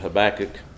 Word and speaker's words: Habakkuk: I pronounce it Habakkuk.